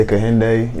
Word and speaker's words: Kehinde: For most people, you know what I'm going to say? I'm going to say just say Kehinde. You know what I'm saying? Great. Oh, how you actually say your name, you know? But, like, I'm still learning how to say my name for a low key, so...